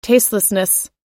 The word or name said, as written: tastelessness